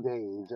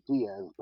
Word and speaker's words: Deas: And so I had those three, and then the Nathaniel Days, everybody pronounces it Diaz, but it's Days. So like Days, yeah. – D-E-A-S, but it's Days.